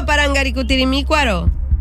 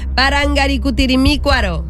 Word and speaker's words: Parangaricutirimícuaro: Ok, repite conmigo, Parangaricutirimícuaro. – Vamos otra vez. – Te sigue faltando una sílaba. Parangaricutirimícuaro. – Despacio, dilo despacio. – Parangaricutirimícuaro. – ¡Eso! – ¡Dilo, sí, muy bien!